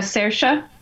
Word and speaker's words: Saoirse: Is Sh- Shersha Ronan? Uh, Saoirse.